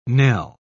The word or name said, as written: knell